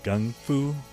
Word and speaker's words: gung fu: Now, I don't know about you, but when I was a kid, I was cuckoo for kung fu. Or maybe I should say I was gaga for gung fu, depending on whether or not you want to pronounce it with its correct Chinese pronunciation.